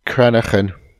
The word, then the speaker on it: cranachan: Hold on. It's pronounced cranachan.